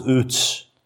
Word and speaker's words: Uz: Now, Job lived in this land called Uz, pronounced Utz.